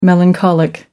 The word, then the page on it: melancholic: American English